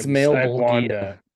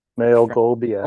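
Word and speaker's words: Malebolgia: It's Malbolgia. – Malbolgia. – Oh, Malbolgia. Malbolgia. – That's what I call that's what it's called in the Malbolgia. – The Malbolgia.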